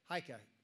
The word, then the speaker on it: Heike: Heike. – Could you spell that for me? – H-E-I-K-E.